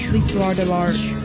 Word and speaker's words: L'histoire de l'art: L'histoire de l'art. It's French. You didn't say it right.